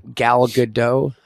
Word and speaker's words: Gal Gadot: Gal Gadot, I believe the pronunciation is.